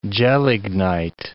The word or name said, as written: gelignite